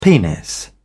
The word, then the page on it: penis: British English